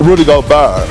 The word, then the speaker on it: Gobert: Rudy Gobert.